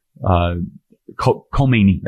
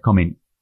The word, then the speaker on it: Khomeini: Khomeini. That's right. Khomeini.